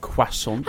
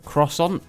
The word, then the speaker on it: croissant: Do the French pronounce it croissant? – Croissant. Croissant. – Like quack, but son.